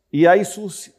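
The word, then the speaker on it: Iesous: Iesus. Iesus is the correct way to say Jesus in Koine Greek, or Yeshua.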